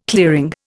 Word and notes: clearing: Indian

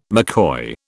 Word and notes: McCoy: British English

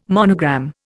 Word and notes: monogram: Indian

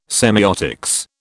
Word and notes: semeiotics: British English

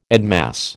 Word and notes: admass: American English

